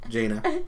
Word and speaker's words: Jaina: Jaina.